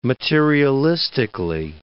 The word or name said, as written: materialistically